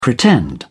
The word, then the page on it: pretend: British English